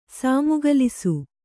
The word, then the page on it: ಸಾಮುಗಲಿಸು: sāmugalisu